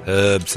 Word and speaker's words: herbs: Herbs. There's an H in it.